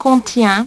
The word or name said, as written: contient